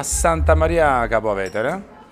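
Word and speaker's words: Santa Maria Capua Vetere: Derivati, allora ci troviamo a Santa Maria Capo Vetere Giusto? Sì. Ho detto bene, sì,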